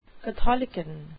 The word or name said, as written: catholicon